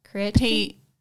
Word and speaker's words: Creatopy: okay, I'm going to spell it. C-R-E-A-T-O-P-Y. – Creatipi. Creatipi. – Yeah, spell that right. – Or like as an Australian, Creatipi. The T is too much.